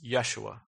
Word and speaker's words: Yeshua: He brought them into the promised land under the leadership of Joshua, which in Hebrew is pronounced Yeshua.